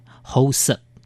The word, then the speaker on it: 厚食: 厚实用客家话来发音，厚实。